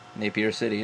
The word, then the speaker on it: Napier: Napier City? – Napier City, yeah. – There you go. – So they're out of Napier City, New Zealand.